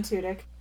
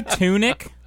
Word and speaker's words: Tudyk: Yeah, Alan Tudyk. – Thank you. I yes, you know Alan Tudyk. I said that wrong. – Did you say tunic? – Don't.